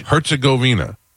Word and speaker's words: Herzegovina: Herzegovina.